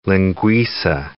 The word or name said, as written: linguica